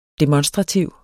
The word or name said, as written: demonstrativ